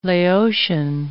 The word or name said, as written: Laotian